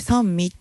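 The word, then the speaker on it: 三位: 1 位、はい、2 位、 3 味。はい、 3 位と書いて 3 味って読むんですけど、うん、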